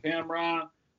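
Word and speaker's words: Camron: You can find me everywhere on social media at Cameron Smith. No E in Cameron. Again, as I always like to say, Cameron, the rapper, made my name cool. Pronounce it Cameron.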